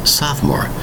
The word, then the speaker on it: sophomore: Notice the spelling of the word sophomore. Three distinct syllables. Spelled S-O-P-H-O-M-O-R-E. That's right, a P-H. Has the same kind of sound as an F. It's spelled S-O-P-H-O-M-O-R-E.